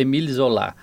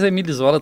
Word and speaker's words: Émile Zola: A pronúncia francesada seria Émile Zola. Mas Émile Zola também vale.